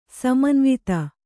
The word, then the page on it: ಸಮನ್ವಿತ: Kannada